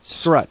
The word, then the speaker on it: Strut: AZ Scrub? – Strut. And I'm going to spell that for you. AZ is in Arizona, right? – Yep. – STRUT, like struts on a car.